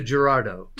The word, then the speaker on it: Girardeau: Girardo. I couldn't even. A- I, anglicized to Gerardo.